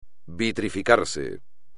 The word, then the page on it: vitrificarse: espagnol